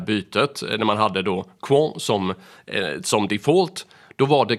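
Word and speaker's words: Qwant: Sen så har vi också Europaparlamentet, och det där var ganska kul, för de har nyss bytt ut Google mot franska Quant, Jag var faktiskt tvungen att fråga äldsta sonen hur man skulle uttala det där, men det ska vara Quan.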